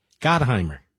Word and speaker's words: Gottheimer: Now, I have to admit, I don't agree with him on 90% of this stuff, but this guy Gottheimer, isn't that how you pronounce it, Mr. Producer? Gottheimer.